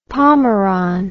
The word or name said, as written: pomeron